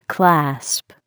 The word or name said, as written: clasp